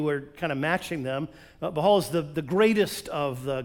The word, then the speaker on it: Baal: I just was looking it up to make sure I really knew, and I forgot that there was a silent H, not silent, a very quiet H in the middle. It's Baal. So they worshipped Baal.